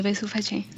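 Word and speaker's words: Bezu Fache: Entonces, el inspector de la policía, llamado Besufache, bueno, ese está más fácil de pronunciar, ¿no? – Así ve su fache.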